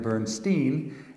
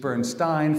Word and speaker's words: Bernstein: So it would only be fair to his parents if we called him Leonard Bernstein, and he used Bernstein for most of his life. Is that enough on that? I just want to make sure everybody knows there's a legitimate case for either pronunciation of his name.